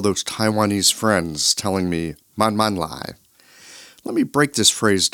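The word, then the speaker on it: man, man, lai (慢慢來): Man, that's pronounced with a fourth falling tone. It means slow. Double up the word, man, man. It means like really slow. Lai, second tone. That means to arrive.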